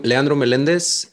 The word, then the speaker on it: Leandro Melendez: And don't worry, you made a great pronunciation of my name, way better than what I'm used to. So that was pretty good. Okay. Yeah, repeating, I'm Leandro Melendez,